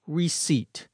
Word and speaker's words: receipt: The bank teller takes the check from Antonio and gives Antonio a receipt, R-E-C-E-I-P-T. The P is silent.